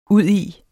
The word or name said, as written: udi